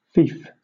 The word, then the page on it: fief: British English